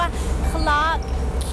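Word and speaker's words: Clarke Quay: คลอกคีสะกดหน้าตาแปลกๆนิดนึงนะ QUAY แต่คำนี้อ่านว่าคีเหมือนคีกุญแจละค่ะ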